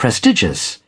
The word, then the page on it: abnormal: /æbˈnɔː(r)m(ə)l/